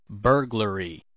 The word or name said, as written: burglary